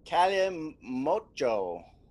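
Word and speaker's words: Kalimotxo: It is a Cali Mocho. I know it has an X in it, but it's pronounced Cho.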